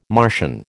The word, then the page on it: Martian: American English